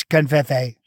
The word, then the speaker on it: covfefe: It's Covfefe. – So wait, is it Covfefe? – Covfefe.